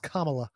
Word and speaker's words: Kamala: It's like Kamala Harris. Apparently it's Kamala.